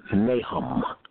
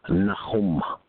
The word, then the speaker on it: Nahum: In the book of Nahum, some of you all may, hallelujah, hallelujah, you may pronounce it Nahum, Nahum, hallelujah, spelled N-A-H-U-M.